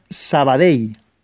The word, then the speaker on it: Sabadell: Por tanto, Carbonell se pronuncia Carbonell y Sabadell se pronuncia Sabadell, acabado en elle, como en una EI e si queréis, Sabadell, Carbonell eh, y demás.